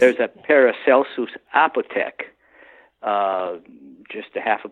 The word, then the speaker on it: Paracelsus: Paracelsus, yes.